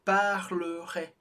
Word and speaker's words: parlerait: A gente tem parlerait. A gente não pronunciou o NT do final, mas eles têm que estar ali para marcar a distinção singular, plural.